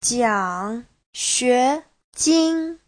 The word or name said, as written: jiang3xue2jin1